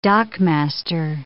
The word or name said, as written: dockmaster